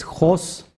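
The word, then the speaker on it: jos (ὅς): Y la palabra que tú ves griega es jos, que se escribe como si fuera, se ve como si fuera una OC, pero en realidad es Jos.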